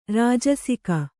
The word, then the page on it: ರಾಜಸಿಕ: rājasika; Kannada